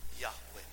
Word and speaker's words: Yahweh: Now, as we look at this, the word Lord in your English Bible is spelled with capital L-O-R-D. But in the Hebrew text, it's written this way. It's Yod, He, Va, He. And they pronounce it, well, they weren't supposed to pronounce the name of the Lord, but we pronounce it Yahweh.